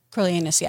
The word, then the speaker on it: Coriolanus: I thought he was so good as Coriolanus. Is that how you say it? – Coriolanus, yeah.